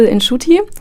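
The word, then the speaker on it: Inshuti: Genau, euer Film hat ja den Titel In Schuti, was Freunde auf Kinya Ruanda bedeutet, also die Sprache, die in Ruanda gesprochen wird. Ich hoffe, ich habe das jetzt einigermaßen richtig ausgesprochen. – Nach meinen Kenntnissen, ja.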